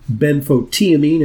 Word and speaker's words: benfotiamine: So in part two, I've got a lot of specific questions on the subject of benfotiamine, as you pronounce it, and yes.